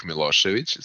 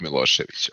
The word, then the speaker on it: Milošević: So that is how you pronounce my name in Serbian. I don't know if there is a way how to pronounce that in English, but let's just stay with Vuk Milošević.